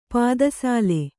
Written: ಪೀತಸಾಲ

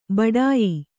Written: ಬಡಾಯಿ

